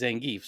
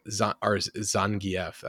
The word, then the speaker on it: Zangief: Let's hype up Zangief, I think is how they say it. I've never heard it spoken until I watched the movies and I was like, oh, I said Zangief for like 10 years.